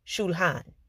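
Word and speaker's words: shulchan: Table in Hebrew is pronounced shulhan.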